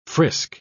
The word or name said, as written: frisk